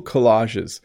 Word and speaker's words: collage: I've made a few cool collages in my life. So again, collage, that's how you would pronounce that.